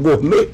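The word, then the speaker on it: Gonet: Está sendo ao vivo agora para dar prosseguimento, né, a uma investigação Gonner. É Gonner o nome dele, é? Gournet. É que se pronuncia como Gournet.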